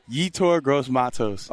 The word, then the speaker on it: Yetur Gross-Matos: Yetur Gross-Matos.